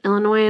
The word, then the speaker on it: Illinois: I have Illinois in my story, and I was like, okay, hopefully I remember not to say the S.